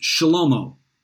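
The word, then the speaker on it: Shlomo (שלמה): Now, the name Solomon in the Hebrew is pronounced Shlomo.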